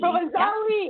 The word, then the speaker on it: Provenzale: Provenzale, you say the E. – Provenzale.